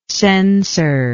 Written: censorian

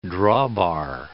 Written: drawbar

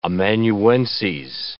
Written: amanuenses

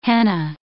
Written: henna

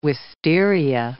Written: wistaria

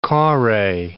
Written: kore

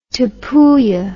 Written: Tapuya

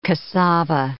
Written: cassava

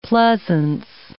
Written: pleasance

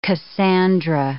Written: Cassandra

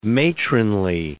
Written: matronly

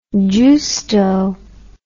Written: giusto

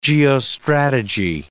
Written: geostrategy

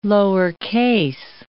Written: lowercase

